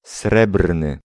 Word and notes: srebrny: [ˈsrɛbrnɨ]

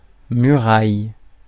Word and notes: muraille: France; [my.ʁaj]

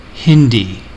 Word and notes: Hindi: American English; /ˈhɪndi/

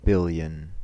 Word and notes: billion: American English; /ˈbɪl.jən/

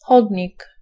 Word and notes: trottoir: France; [tʁɔ.twaʁ]

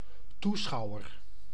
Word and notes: spectateur: [ɛ̃ spɛk.ta.tœʁ]; /spɛk.ta.tœʁ/

